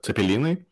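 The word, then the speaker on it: cepelinai: Well, it's if you want to call it in a more Lithuanian way, you call it Zeppelini.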